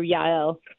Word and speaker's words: Jael: Is it J-L or Jael? – I don't even know how it's supposed to be pronounced in English. I usually go with the Hebrew Yael.